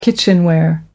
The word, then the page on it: kitchenware: American English